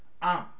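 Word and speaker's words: Am (עם): Am is how you pronounce it.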